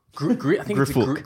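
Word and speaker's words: Griphook: Is it pronounced Grip Hook or Grip Hook? – Griffook.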